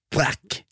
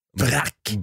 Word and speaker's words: Braque: Jag tror att det är så man uttalar det. – Braque. – Nej, B-R-A-Q-U-E. Jag tror det är Braque. – Braque blir, okej. Hårt Q.